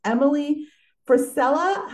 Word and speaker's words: Frisella: She is doing so many amazing things, and her name is Emily Frisella.